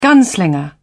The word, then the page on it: gunslinger: British English